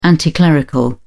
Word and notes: anticlerical: British English

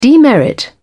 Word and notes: demerit: British English

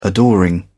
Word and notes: adoring: British English

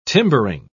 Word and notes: timbering: British English

